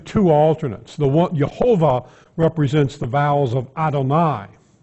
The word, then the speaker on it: Jehovah: And so they started actually pronouncing the word the way it was pointed, Jehovah, instead of reading the rabbis wanted them to read.